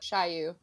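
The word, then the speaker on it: Shyu: Yeah, it's spelled Shu, but Shiyu. – They literally wrote Shy, S-H-Y, and then added U at the end.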